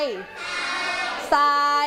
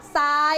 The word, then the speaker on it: ทราย: ทรายทอรอตัวนี้เมื่อรวมกันแล้วจะออกเสียงเหมือนซอโซ่เลยเวลาเราออกเสียงซึ่งเราจะเรียนในอีกชั้นที่สูงขึ้นนะคะว่าทำไมทอรอจึงจะออกเสียงเป็นซอนะคะอ่านอีกหนึ่งครั้งทราย